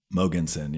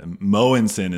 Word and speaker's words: Mogensen: Mogensen. Yeah. Mogensen is actually how you pronounce it in Danish, which is where my father was born and raised.